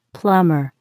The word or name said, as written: plumber